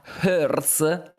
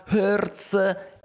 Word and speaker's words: Hertz: Elon Musk faceva battute dicendo: Ah, ah Hertz, che chiaramente è la pronuncia inglese, che significa in inglese mm, fa male, ok?